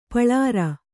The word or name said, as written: ಪುಲ್ಲೋರಿ